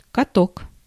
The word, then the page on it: mangle: /ˈmæŋ.ɡəl/